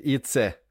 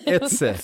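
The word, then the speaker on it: Eze: Här gjorde han i alla fall ett, och Eberechi Itse. Vilket konstigt uttal av det namnet. Etse.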